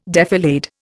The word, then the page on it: defilade: Indian